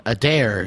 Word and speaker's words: Adair: I also Emily brought us back some of your newest cheese called Adair. Is that a correct pronunciation? – Yes, and it's an old Scottish surname that's common in my family.